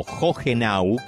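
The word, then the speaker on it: Hohenau: Ustedes saben que Joenau, o Jogenau, como dice que se pronuncia, por lo menos eso me dijeron desde Alemania, que ellos conformaron después el resto de las colonias que conforman hoy las Colonias Unidas.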